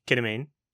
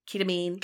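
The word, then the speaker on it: Kita Mean: And now I'm hearing like, what's her fucking, Kida? – Ketamine. – Ketamine.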